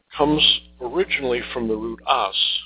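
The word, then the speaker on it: ās: Now, this comes from the word as. This is a long A, the A with a long mark over it, A, S, as.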